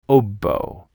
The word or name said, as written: oboe